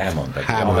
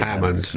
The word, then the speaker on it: Hammond: Hammond volt mindenhol, ezt most már... – De a Hammond orgon az egy legenda, persze. Tehát Hammond vagy Hammond? – Hammond. Amerikai. – Hammond. – Nem német, nem holland.